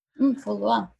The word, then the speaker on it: Foulois: But in 1911, the pilot Benjamin Foley, I'm not sure if I'm pronouncing that. – Foley? – Foley or Foulois? – Foulois. – If you read it in French.